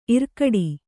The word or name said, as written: ಇರ್ಕಡೆ